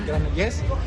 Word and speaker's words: Granollers: Yo he estado viviendo en Granoles, como te digo, Granoles, ¿o cómo se pronuncia? – Española. – Granollers.